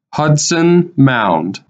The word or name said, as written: Hudson Mound